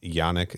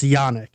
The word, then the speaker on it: Yannick: But this final battle scene where Shaw, after the ship has crashed, after um, I keep saying Janik. It's Yannick. Is that the correct pronunciation? – it's it's Yannick.